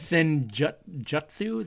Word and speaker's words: Senjutsu: Senjutsu, is that how you say it? – Yeah.